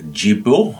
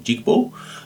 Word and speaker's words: Djigbo: The first part was called Jigbo, D-J-I-G-B-O, Jigbo, by the Helsinki Kotono Ensemble, difficult to pronounce, but all good, from Helsinki, and originally based out of Benin in West Africa.